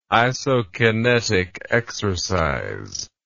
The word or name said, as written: isokinetic exercise